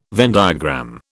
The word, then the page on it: Venn diagram: British English